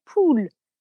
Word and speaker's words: poule: So how do you say pool in French? – Pool. That's it, isn't it? Pool. – It is. except, you know what it means, P-O-U-L-E.